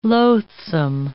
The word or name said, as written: loathsome